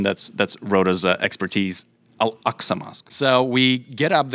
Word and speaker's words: Al-Aqsa: It has the Al-Aqsa Mosque. Did I say that right? – So, yes, you did.